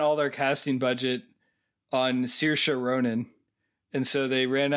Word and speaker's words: Saoirse: So I think that's how you say it. Saoirse. It's like it's an Irish name. – Meaning it has all kinds of letters in there. – S-A-O-I-R-S-E is what I think the spelling is.